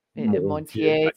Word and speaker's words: Montagnier: How did you say his name? Montier, spelled M-O-N-T-A-G-N-I-E-R, and water memory, like you pull them right up.